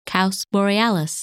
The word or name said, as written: Kaus Borealis